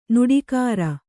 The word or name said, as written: ನೀಟುಗಾರ